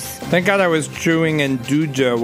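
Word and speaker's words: nduja: Induja is how I like to pronounce it.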